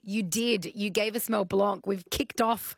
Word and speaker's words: Mel Blanc: Mel Blanc.